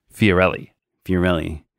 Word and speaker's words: Fiorelli: Fiorelli. Am I saying that correctly? – Fiorelli.